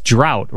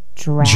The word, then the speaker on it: draught: All right, I'm confused because I said that the title of this show is A Sleeping Draught because it is spelled D-R-A-U-G-H-T. Now, that's on every log. That's on every escape log. It's spelled D-R-A-U-G-H-T, and that's drought, right? A sleeping drought. – Well, that's how you spell the word drought, and he said draft. – Draft. The announcer said a sleeping draft.